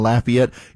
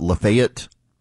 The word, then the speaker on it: Lafayette: Lafayette is Lafayette. For those of you in Louisiana right now listening in Lafayette, in Georgia, they pronounce it Lafayette. I've had to learn all these years of being in Georgia, but being a native of Louisiana, it is always, always going to be Lafayette.